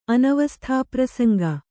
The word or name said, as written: ಅನವಸ್ಥಾಪ್ರಸಂಗ